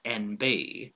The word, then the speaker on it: ENBY: It's spelt ENBY, and I believe it's pronounced NB. What is this show about? Why did you create it? – The NB show. It is, so NB is the phonetic, obviously, of the letters N and B, which stands for non-binary.